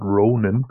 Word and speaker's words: Ronin: Die richtige Aussprache ist hier in der Tat Ronin. Ähm, äh, ja, ganz einfach deshalb, weil das äh, Wort äh, Ronin für äh, das Wort für einen herrenlosen Samurai ist.